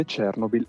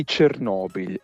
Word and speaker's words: Chernobyl: Poi però ci è stato fatto notare che Chernobyl è una pronuncia sbagliata. Allora, innanzitutto è sbagliata perché l'accento tonico deve cadere correttamente sulla penultima sillaba, sulla O. Quindi Chernobyl eventualmente, anche se poi anche Chernobyl non è la pronuncia ideale.